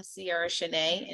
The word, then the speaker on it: Ciara Shanae: So they can find me everywhere at I am Ciara Shanae. And my first name is spelled like the singer, right? C-I-A-R-A. And then it's S-H-A-N-A-E.